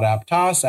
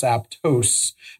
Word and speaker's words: Aptos: I report to the CMO for a retail technology company called Aptos, not Aptos, Aptos. Aptos, I'm very sensitive about that.